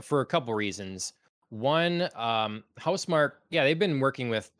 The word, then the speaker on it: Housemarque: fact that Sony has acquired the Returnal developer Housemark, I believe is how you say it. I don't think it's Housemarque.